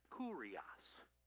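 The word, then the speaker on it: kurios (κύριος): That's how that's the English letters, but the way you pronounce it is kurios. So say that with me, please. Kurios. Kurios. good way to remember it. It fits our English curious, but you don't have the ear, you just have the er with this one.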